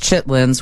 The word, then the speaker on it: chitterlings: I know they're spelled chitterlings, but they're pronounced chitlins.